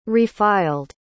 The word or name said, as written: refiled